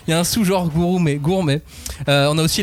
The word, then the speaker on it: gourmet: Bon, yashiki Manga peut-être, mais euh, il y en a, si je vous dis, les Gourumés. Les ah. gourmets, prononce-le très vite. gourmet.